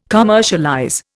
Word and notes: commercialize: Indian

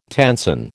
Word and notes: tenson: American English

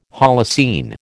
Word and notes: Holocene: American English

